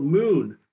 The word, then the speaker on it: MXXN: We've been talking to Darnell Smith from Mojo, and they have come out with their first product called Moon, and that's spelled MXXN.